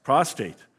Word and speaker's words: prostrate: Careful word. I have to say that correctly. Prostrate. Prostrate. Okay, I had it wrong.